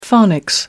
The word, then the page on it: phonics: American English